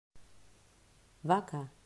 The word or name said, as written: Vaca